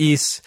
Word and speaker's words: Ys: And we knew it was called Ys because we had the katakana and it had the pronunciation right there.